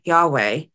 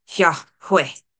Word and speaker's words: Yahweh: One of my Bible teachers growing up once told me that one way to pronounce God's name, Yahweh, is with two quick exhales, Yahweh.